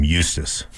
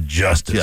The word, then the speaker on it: Justus: Plus, they made a classic mistake by calling him Justus. Should have been Justice on him. If you'd have just come out and said, no, it's pronounced Justice.